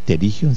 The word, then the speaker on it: pterigión: Pterigión se escribe con P primero, P de Pedro, luego T de Tomás, P-T-E-R-I-G-I-O-N, pterigión.